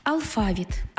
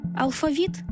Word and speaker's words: алфавіт: Наголос у слові «алфавіт» на другому складі основний у нашій мові, бо збігається з грецьким «алфавітон», від якого й походить українська лексема.